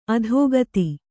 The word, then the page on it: ಅಧಿಗತ: Kannada